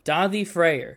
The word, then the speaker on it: Daði Freyr: How you pronounce that name? – Daði Freyr. It's Icelandic.